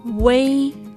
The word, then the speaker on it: wei: Se pronuncia wei, wei como si hubiera una E entre la U y la I, en otras palabras, como una combinación de la vocal simple U y la compuesta E, Wei. De este modo, pronunciamos primero la U y luego cambiamos la forma de la boca para lograr la E.